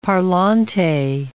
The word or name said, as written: parlante